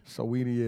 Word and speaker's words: Saweetie: Sweetie or Swati. I forgot how you pronounce her name. – Saweetie, yeah.